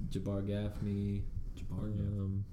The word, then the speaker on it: Jabbar: Used to say Jabber. When I was a kid, I'd be like, hey, I got Jabber Gaffney on my Madden team.